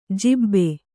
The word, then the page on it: ಜಿಬ್ಬೆ: jibbe